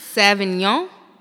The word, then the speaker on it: Sauvignon: I think it's Sauvignon. – That sounds correct. – Maybe. I think. – That sounds correct. Cabernet. – Yeah. And it's like S-A-U.